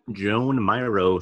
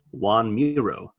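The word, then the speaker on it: Joan Miró: He asks if she is near the Joan Miro statue, Moonbird, which he is next to. A student passing by corrects Homer and says that it's pronounced Juan Miro, while another student calls him a stupid townie.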